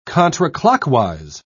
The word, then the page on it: contraclockwise: British English